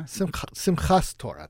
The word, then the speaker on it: Simchas Torah: I'm not sure I'm going to pronounce this right. Simchas Torah Time? – Simchas Torah Time. – There you go.